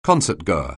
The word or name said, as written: concertgoer